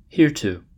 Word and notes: hereto: American English

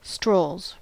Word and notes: strolls: American English

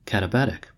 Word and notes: katabatic: American English

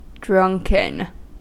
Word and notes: drunken: American English

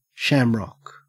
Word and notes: shamrock: Australian English

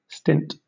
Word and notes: stint: British English

